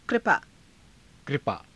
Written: kripaa

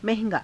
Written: naha~gaa

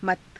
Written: mata

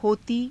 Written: hotii

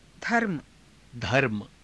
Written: dharma

